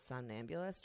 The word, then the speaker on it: somnambulist: That's where the word zine comes from, Z-I-N-E. Also, kudos to you because you pronounce somnambulist right. – Yeah. – So many people do not pronounce that one.